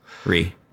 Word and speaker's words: jewelry: Jewelry. – Just two syllables. – Jewelry.